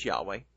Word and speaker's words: Yahweh: My point here is that based upon the textual evidence, I have no choice but to believe that YHWH, and I pronounce these letters as Yahweh, but I have no choice to believe that those letters form the name of the Heavenly Father.